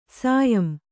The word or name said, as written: ಸಾಯಂ